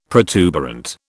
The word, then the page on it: protuberant: British English